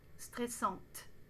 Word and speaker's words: stressant: Stressante. N'oubliez pas, après C, on utilise un adjectif masculin singulier, donc dites c'est stressant. It's stressful. Ne prononcez pas le T.